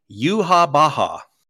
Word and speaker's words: Yhwach: How do you say the main guy's name? Yawak. – So his name, it's pronounced. I looked this up just now because we're going to put this on a list. Because this is one of these names that has to be on our nomination list. It's spelled Y-H-W-A-C-H, but it's pronounced Yuha Baha.